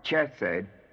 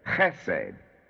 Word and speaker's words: Chesed: Four is Chesed, not Chesed, but Chesed. Chesed, the word itself means mercy.